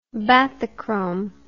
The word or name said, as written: bathychrome